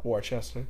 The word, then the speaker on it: Worcester: But yeah, that was when I also learned to pronounce Worcester and not how it's spelled. – Worcester.